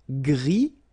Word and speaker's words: gris: Gris, don't pronounce the S, but then for the feminine form, grise.